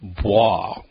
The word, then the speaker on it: Bloy: It's spelled B-L-O-Y. Now, explain to us, why is it pronounced Bloch? – Oh, my. Well, I would say in keeping with the rules of French, which, as anyone who's ever tried to learn the language knows, can be pretty arcane. – So the L is sounds like a W? – Well, it just kind of softens in the course of pronunciation, I'd say. – So it becomes a very soft, kind of mushy L? – Yeah.